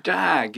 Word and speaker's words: Dag: His name is Dag Elias, as how I would say it in Swedish. How do you say it here? – Well, Dag is kind of what it ended up being, and I'm cool with that.